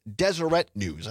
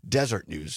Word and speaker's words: Deseret News: The correct pronunciation is Deseret News. I think I was pronouncing it Desert News.